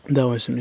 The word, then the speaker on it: Taoism: Taoism, yes, you pronounce it like that.